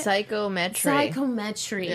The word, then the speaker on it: psychometry: Also, we see that Maggie now has psychometry is what we pronounce, how we pronounce it. – Psychometry.